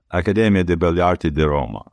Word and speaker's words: Accademia di Belle Arti di Roma: Accademia di Belle Arti di Roma, that's mm-hmm.